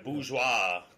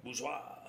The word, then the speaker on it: bourgeois: It's a bourgeois type meal. Is that I say that right, my French translator? – Yeah, that's all right. – Bourgeois, bourgeois, yeah.